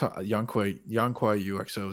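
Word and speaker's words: Yanqui U.X.O.: I hadn't really experienced, how do you pronounce their third album again? Yonkui UXO? – Yeah, that's Yonkui UXO is how I've been pronouncing it.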